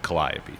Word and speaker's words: Calliope: Um, I named it Calliope Beer Works after the Muse of Music, uh, which is pronounced Calliope. So that's how I say it.